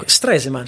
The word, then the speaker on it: Stresemann: Innanzitutto con i lavori di Stresemann, ministro famosissimo, ministro degli esteri tedesco, un ruolo fondamentale. – Che io non so pronunciare mai. No, Stresemann, semplice.